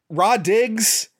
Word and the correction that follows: Rah Digga: you're not a good actress. I'm sorry, I'm totally getting her name wrong. It's Rah Digga.